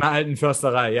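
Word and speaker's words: Alten Försterei: Is it the Stadion Ander Alten Forsterei or something like that, isn't it? Is that close? – Stadion Alten Forsterei, yeah.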